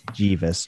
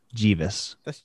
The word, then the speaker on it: Geeves: Jeeves. G-E-E-V-E-S. We'll spell it. Jeeves. My name is Jeeves.